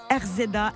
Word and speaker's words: RZA: Alors comment tu le prononces RZA, a si tu veux.